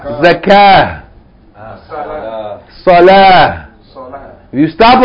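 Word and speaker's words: Salah (صلاة): Not if you stop on it. We don't say salat. You say salah.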